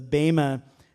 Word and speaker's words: bema: Did you know that that word judgment there is bema, or some pastors will say bema because it's spelled B-E-M-A, but I think it's more correct to say bema. B-A-Y-M-A is the pronunciation, bema, seat of Christ.